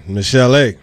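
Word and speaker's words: Michel'le: I think I'm saying this right. Michelle E. – Man, listen. – Is that right? – Listen, Michelle A.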